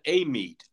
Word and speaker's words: Amite: Apparently, they call it Amite, which, you know, it's A-M-I-T-E, which I would never think Amite, but Louisiana is weird in that way.